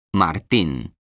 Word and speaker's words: Martín: Se escribe J-U-L-I-A. ¿Y tú? ¿Cómo te llamas? – Me llamo Martín. Se escribe M-A-R-T-I-N.